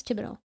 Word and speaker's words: Thibodeau: I, I, am pretty sure he actually says Thibodeau. It's that's yeah. that's it's spelled differently, it.